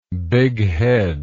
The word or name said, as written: bigheadedness